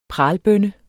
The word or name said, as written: pralbønne